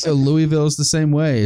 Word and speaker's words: Louisville: It's Louisville, I guess I should be pronouncing it.